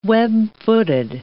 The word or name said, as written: web-footed